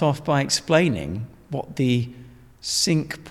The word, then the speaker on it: Cinque: Just on pronunciation, we need to make this clear, don't we, that it's Cinque. – Yes. – In English, but so Cinque in French. – Yes, absolutely. So it reads as Cinque Ports, and people tend to want to say Cinque Ports because we know that's what the French for five would be.